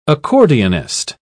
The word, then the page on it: accordionist: British English